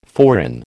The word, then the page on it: foreign: American English